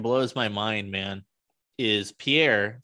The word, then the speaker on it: Pierre: Pierre. Just the like a pier, like a water pier kind of. – That's fucking stupid. I'm going to call it Pierre.